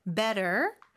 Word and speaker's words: better: better의 T 발음은 모음 소리 사이에 껴서 리을처럼 부드럽게 발음된다는 거, better now.